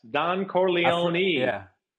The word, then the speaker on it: Don Corleone: I think I do the same thing sometimes, and I don't know why, but yes, Don Corleone.